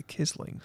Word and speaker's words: Quisling: Quisling is the way most, in fact, all English speakers pronounce it. But I think in Norwegian it's actually Kisling.